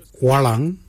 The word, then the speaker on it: walyan: The first colour is walang. Walang is blue. Walang is spelled W-A-L-Y-A-N.